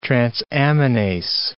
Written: transaminase

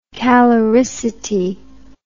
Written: caloricity

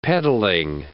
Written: peddling

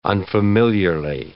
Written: unfamiliarly

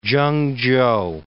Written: Chengchow

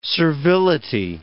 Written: servility

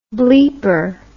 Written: bleeper

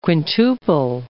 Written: quintuple

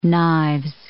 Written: knives

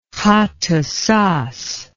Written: Khattusas

